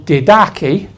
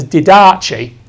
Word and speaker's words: Didache: The Didache, which when I used to read this, I used to read it as Didache, but it's Didache.